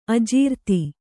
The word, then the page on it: ಅಜಿರ್ತೆ: Kannada